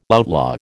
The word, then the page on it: outlaw: American English